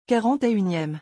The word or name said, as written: 41eme